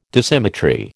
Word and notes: Dissymmetry: American English

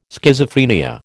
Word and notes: Schizophrenia: American English

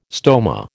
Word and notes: stoma: American English

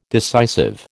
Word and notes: decisive: American English

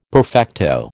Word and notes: perfecto: American English

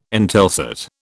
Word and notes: Intelsat: British English

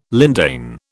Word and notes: lindane: British English

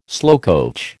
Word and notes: slowcoach: American English

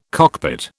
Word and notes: cockpit: British English

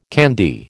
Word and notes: candy: American English

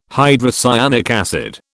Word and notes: hydrocyanic acid: British English

